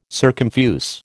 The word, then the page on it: circumfuse: American English